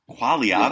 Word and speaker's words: Quagliata: So if I was close on the last name, how do you pronounce the last name? – The G is silent. – For real? – Quagliata? Yeah. – Quagliata.